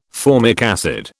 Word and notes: formic acid: British English

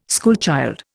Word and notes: schoolchild: Indian

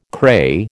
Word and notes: cray: American English

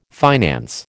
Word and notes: finance: American English

